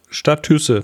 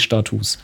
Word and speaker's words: Status: Statüsse, bitte. – Der Plural von Status ist Status. – Echt? – Ja. – Was? – Ja, es schreibt sich genau wie Status, aber spricht sich Status. Ist so.